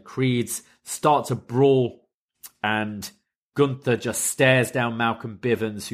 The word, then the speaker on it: Gunther: And then Imperium's music hit and they come out and Gunther says, "You will not speak of Imperium in this fashion." And Bivens once again calls him Gunner. He goes, "It's pronounced Gunther.